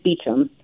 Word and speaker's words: Beauchamp: There seems to be a dispute in South Louisiana that B-E-A-U is a little tricky for everyone, but it is Beecham.